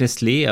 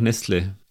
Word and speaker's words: Nestlé: Ja, eigentlich Nestlé, aber irgendwie im Sprachgebrauch Nestle. Ja, ein E mit Akzent, also mit langes E.